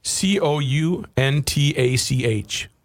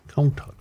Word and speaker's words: Countach: Countach? – I don't know.